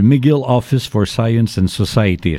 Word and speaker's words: McGill Office for Science and Society: Et je rappelle que tu es membre du McGill Office for Science and Society.